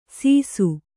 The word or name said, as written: ಸಿಸ್ಸು